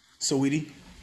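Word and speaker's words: Saweetie: Is it like Sweetie or is it Sweet? See, I don't even know how to pronounce her name. – Sweetie. – Sweetie. That's stupid. – Yeah, her name is Sweetie.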